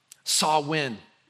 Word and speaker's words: Samhain: Samhain is how it's pronounced.